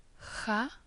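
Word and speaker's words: Kha (خاء): The last letter, Kha, is pronounced as the final part of the Scottish word loch, or the beginning of the Swedish word sjö.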